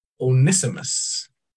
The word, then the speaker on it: Onesimus: I am appealing to you for my child, Onesimus. Is that how you would pronounce that? – Yes. – Onesimus.